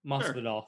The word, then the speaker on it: Masvidal: Am I pronouncing that right? – Masvidal. – Sure. Masvidal. All right.